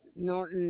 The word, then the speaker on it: Norton: Elizabeth Norton Neto.